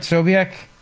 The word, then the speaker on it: Sobiech: That's Jack Sobiech, right? Am I saying that right? Sobiech? – I'm always anxious about the pronunciation, but yeah, I'm like 99.9% sure you're correct.